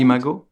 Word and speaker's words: Imago: Another project I saw on your website I'd love to talk about or hear about, which is Amigos. Am I saying it right? – Imago, yeah.